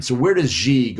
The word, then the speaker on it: Xi: For Xi, am I pronouncing that right, Dex? Xi? Xi, okay. I pronounced it wrong the other day. Dex like, no, it's like this.